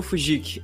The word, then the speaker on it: Fujiki: Olha, gente, quem quer me seguir, o meu Instagram é arroba Fujik, F-U-J-I-K-I, caso alguém tenha dificuldade, né, pela pronúncia.